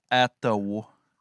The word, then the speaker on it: Ottawa: Un band qui vient de Ottawa. Une façon de prononcer le W entre les deux A. Sérieux, je sais pas. Là. – Ça se prononce Ottawa.